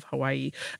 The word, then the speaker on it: Hawaii: You do know that in the Hawaiian language, the V sound is the correct way to say the W in the word Hawaii, right?